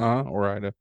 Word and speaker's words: Ore-Ida: Ore-Ida.